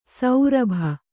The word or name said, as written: ಸೌರಭ